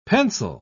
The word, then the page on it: pensile: British English